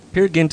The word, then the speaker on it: Peer Gynt: Peer Gynt, sweet morning. – It's Peer Gynt. – Peer Gynt? – It's because it's Norwegian.